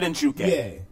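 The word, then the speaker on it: Njoku: Yeah, David Njuke.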